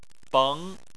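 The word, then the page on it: beng: tone 2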